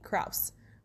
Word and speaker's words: Krause: Because Krause Springs, that looks like it would be K-R-A-U-S-E, Krause, but it's Krause.